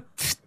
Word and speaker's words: PFT: Et alors, il paraît qu'il faut prononcer le pfft.